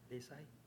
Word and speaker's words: Desai: Nito Desai. – Can you spell your last name for us? – Desai. – Can you spell it for us? – D-E-S-A-I.